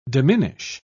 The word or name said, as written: diminish